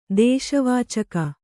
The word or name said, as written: ದೇಶವಾಚಕ